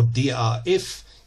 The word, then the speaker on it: DAF: That was Ein bisschen Krieg by DAF or DRF if you wish to pronounce it the German way.